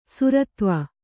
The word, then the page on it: ಸುರತ್ವ: Kannada